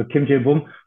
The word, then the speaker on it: Kim Jae-bum: Du hast richtig ausgesprochen, also Kim Jae-bum.